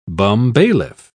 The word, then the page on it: bumbailiff: British English